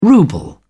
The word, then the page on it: ruble: British English